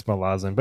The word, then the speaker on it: Malazan: Because he puts the emphasis on the wrong... – The emphasis on the wrong syllable? – Whatever. But I'm pretty sure it's Malazan.